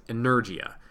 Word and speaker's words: Energia: If we look at it as Americans, it's E-N-E-R-G-I-A. – Okay. – So we would probably pronounce it as Energia. Energia is, I believe, the proper pronunciation.